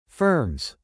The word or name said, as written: firms